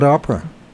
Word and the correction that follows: Oprah: Oprah. I'm sorry, Winfrey? – I said it wrong. – Oprah Winfrey, huh? – This is my accent. Oprah Winfrey.